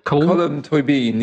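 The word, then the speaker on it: Colm Tóibín: Colm Toibin, is that how you pronounce his name? – Colm Toibin, yeah.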